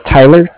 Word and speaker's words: Tyler: My name is Tyler, T-Y-L-E-R. And I'm calling with Cardiff. It's spelled C-A-R-D-I-F-F, as in Frank.